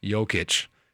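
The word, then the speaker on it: Jokić: Nikola Jokic. – Is his last name Nugget? – No, his last name's Jokic. – I thought that was his whole first name. – No, Nikola, first name. Pause. Jokic.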